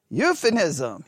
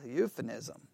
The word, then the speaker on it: euphemism: I'll spell it. E-U-P-H. Euphemism. Yes. Euphemism, right? Euphemism, right? I always want to put an N there. Euphemism, right? Yeah. E-U-P-H-E-M-I-S-M.